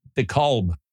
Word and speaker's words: DeKalb: I know there's an L in there, but that's, you know, DeKalb. Just cab. – Oh, okay. – In fact, it's one syllable. Cab. Like skeet. – Skeet. – Yeah, let's go eat a skeet. – That's right. – Cab. Just one word.